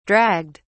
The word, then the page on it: dragged: British English